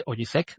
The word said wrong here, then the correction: Osijek: de Croacia. Osijek de Croacia ante el Dinamo Zagreb, el equipo que decíamos que es el único equipo top en esa liga.